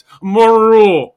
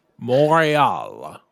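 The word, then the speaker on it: Montreal: They just don't pronounce the T. It's Morial. Anyway, that's what I was saying. – Morial.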